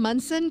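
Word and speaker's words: Monson: I am Kim Munson.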